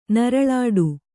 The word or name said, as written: ನರಳಾಟ